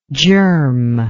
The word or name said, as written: germlike